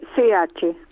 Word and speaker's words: Bosch: Sí, Bosch. – Ah, Bosch, claro, sí. – Sí. – Como las herramientas, sí.